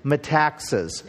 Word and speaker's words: Metaxas: And the man who put this together, his name is Eric Metaxas. It's spelled like me taxes.